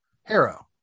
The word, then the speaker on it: Harrow: I always can't, is it Harrow or Harrow? Harrow, because it rhymes with arrow.